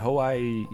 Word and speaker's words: Hawaii: Like we kind of say it as though it's going to be two syllables. But then we realize like, oh no, there's three syllables in that. We're like Hawaii.